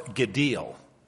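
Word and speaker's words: Gadil (גדיל): The Hebrew word is spelled G-A-D-I-L, and it's pronounced Gadil, Gadil.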